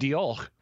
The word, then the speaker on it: Diolch: But he wrote, I would say Dioch. – Yeah. Really? Diolch. Yeah.